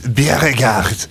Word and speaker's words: Bjerregaard: på Commodore 64 av Rein Overhand och Johannes Bjerregaard. Han är från Danmark, så jag är ganska övertygad om att det uttalas på det viset.